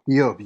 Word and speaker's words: Ayob: Do you say his name, Ayob? – Ayob, yes. Yeah, Job. Job, it's the Amharic name for Job, Ayob.